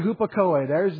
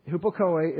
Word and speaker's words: Hupakoe: That's a long E. I should have put the little long bar over the E. Hupakoe is the accusative object of this preposition, which as a unit means for the purpose of obedience.